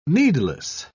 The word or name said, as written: needless